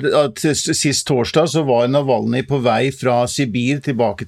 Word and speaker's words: Navalny: den russiske opposisjonspolitikeren Navalny. Er det sånn man uttaler det, Per Olav? – Ja, jeg er ikke så veldig god på russisk, men jeg tror vi sier Aleksej Navalny, ja. Eh,